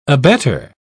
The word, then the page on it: abettor: British English